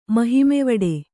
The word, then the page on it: ಮಹಿಮೆವಡೆ: Kannada